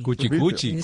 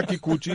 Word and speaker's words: Kikuchi: Kuchikuchi. – Sí. – Se llama Kikuchi, es un pitcher veterano de los Azulejos.